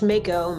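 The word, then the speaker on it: Majko: And you can find me, my name's Paige Mako. My last name is spelled weird. It's M-A-J-K-O. So the J silent.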